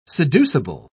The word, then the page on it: seducible: British English